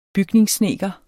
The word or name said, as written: bygningssnedker